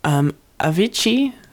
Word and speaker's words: Avicii: So, coming up next, we have Avicii. Is that how you say it? – Yeah.